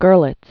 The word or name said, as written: Gorlitz